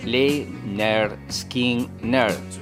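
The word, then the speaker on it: Lynyrd Skynyrd: decidían lanzar el álbum que nos enseña a pronunciar el nombre del grupo. Pronuncias Lay Nerd Skin Nerd.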